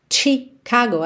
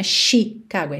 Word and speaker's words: Chicago: Chicago, atenção, a pronúncia dessa palavra tem um ch, ch, não é Chicago, é chi, chi, ok? Tipo tchau, tchau, TCH, Chicago. Western Mail.